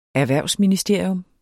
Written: ERHVERVSMINISTERIUM

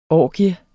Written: orgie

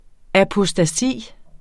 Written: apostasi

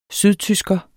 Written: sydtysker

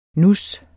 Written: nus

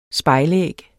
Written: spejlæg

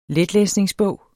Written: letlæsningsbog

